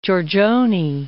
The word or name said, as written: Giorgione